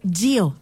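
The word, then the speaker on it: Gyo: Y justo tenemos que hablar de Gio, así se pronuncia.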